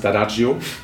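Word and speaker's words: D'Addario: Ob das jetzt Ernie Ball ist, ob das Dadario ist, der Pro sagt übrigens Dadagio. – Dadagio? – Dadagio, genau, können auch viele nicht aussprechen, dann Dadagio, Italiener, ganz klar.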